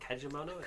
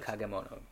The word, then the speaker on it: Kagemono: It's not Kajimono? – Kagemono. Kagemono. Which means shadow things.